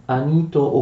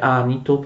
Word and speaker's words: Anito: Chi erano Meleto, Anito oppure Anito, pronunciate come volete, e l'icone.